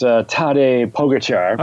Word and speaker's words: Tadej Pogačar: And for me, one of my big storylines coming out of this is the young star, Tadej Pogačar. I think you have the inside line on the correct pronunciation of this young Slovenian's name, don't you? – Oh, well, I've been told it's pronounced Tadej Pogačar.